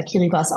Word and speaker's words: Kiribati: Und es war Anote Tong, der frühere Präsident von Kiribati, Kiribati, wie es geschrieben wird, aber Kiribati ausgesprochen.